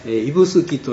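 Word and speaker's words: 指宿: イブスキという、これもまた妙な読み方をしますよね。指の宿と書きまして、もうこれもなんでこんな名前がついたかについては諸説ありまして、あの定かではないんですけどね。はい、一応イブスキ温泉、まあ、逆に覚えてしまうとこう名前にインパクトがありますから忘れにくいところはありますけどね。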